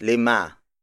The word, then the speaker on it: lima: Up next is lima.